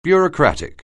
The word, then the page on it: bureaucratic: anglais